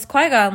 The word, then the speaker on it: Qui-Gon: So, Qui-Gon, the movie starts off with, am I saying his name right? – Yeah, Qui-Gon.